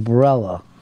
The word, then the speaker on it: Barilla: It sounds like Barilla. Like, it rhymes with umbrella in the ads.